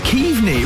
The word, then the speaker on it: Keaveney: It's Keaveney. It rhymes with Steve Lee.